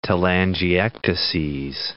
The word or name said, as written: telangiectases